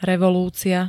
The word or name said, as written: revolúcia